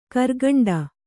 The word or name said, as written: ಗರಿಗಂಟಿ